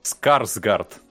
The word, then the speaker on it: Скарсгард: Скаград ведь? Или Сказгард? Боже мой, я загуглю, ну, пожалуй. Пускай Сказгард. – Скарсгард, да.